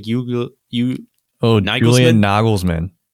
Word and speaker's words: Nagelsmann: Julian Nagelsmann.